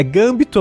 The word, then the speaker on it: gambito: É, é gambito a pronúncia.